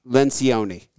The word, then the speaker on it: Lencioni: Lencioni.